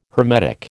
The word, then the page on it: hermetic: American English